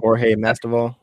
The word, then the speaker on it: Masvidal: Jorge Masvidal.